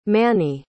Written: mannie

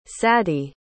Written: sadi

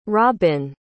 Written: robbin

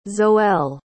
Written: zoelle

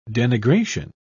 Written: denigration

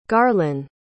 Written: garlyn

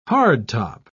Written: hardtop

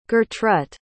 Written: gertrut

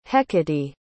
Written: hecate

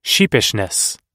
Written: sheepishness

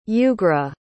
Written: ugra

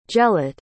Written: gelate

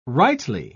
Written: rightly

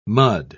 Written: mud

